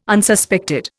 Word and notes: Unsuspected: Indian

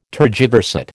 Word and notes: tergiversate: American English

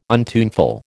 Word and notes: untuneful: American English

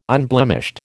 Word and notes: unblemished: American English

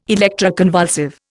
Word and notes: electroconvulsive: Indian

